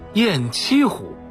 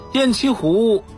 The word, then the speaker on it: 雁栖湖: 听众朋友，今天给您介绍的这个水系啊，叫做雁栖湖，这是咱北京人都熟悉的一座湖，咱老百姓叫白了都给叫成了雁栖湖了。实际上啊，这个地方啊应该读作雁栖湖。雁栖湖位于怀柔城北 8公里，每年的春秋两季常常有成群的大雁来湖中栖息，故而得名雁栖湖。